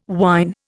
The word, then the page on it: wyn: Indian